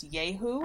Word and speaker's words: Yahoo: Yeah, I believe it is actually supposed to be pronounced Yahoo. – Oh. – Because the term comes from the sound, the screaming sound that these creatures are supposed to make.